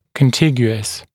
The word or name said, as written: contiguous